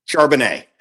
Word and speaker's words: Charbonnet: Give me the pronunciation guide. – Charbonnet. – Thank you very much. That's what I thought.